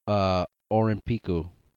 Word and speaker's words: Olympiku: Okay, so the Japanese word for Olympics is Olympiku. – Yeah, that's how you pronounce it. Olympics. Olympiku. – Olympiku. – Olympiku. – Olympiku. – That's how they pronounce it. They're just saying the word Olympics with a Japanese, heavy Japanese accent.